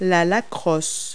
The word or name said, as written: lacrosse